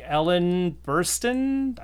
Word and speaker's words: Burstyn: It's a trilogy, and so it's continuing, even with the same cast, like Ellen Burstyn. I think I said that wrong. – Okay. No, you got that right.